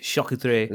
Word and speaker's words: charcuterie: Charcuterie is how you say it in French. Charcuterie.